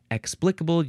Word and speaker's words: explicable: Okay, the next word is explicable. Explicable, you can emphasize the first syllable, explicable.